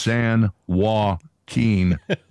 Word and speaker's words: San Joaquin: San Joaquin Valley. That's how it's pronounced. – No, it's not. It's San Joaquin and that's it.